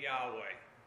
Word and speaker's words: Yahweh: And how do you pronounce that? Well, the Jews took the vowels from Adonai, which is Lord, with the capital L, and they used some of the vowels in there to get Yahweh, which was what we use as Lord in our English Bibles, capital L.